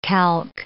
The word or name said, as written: calque